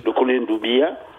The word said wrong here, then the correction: Doumbouya: Mamadi Doumbouya.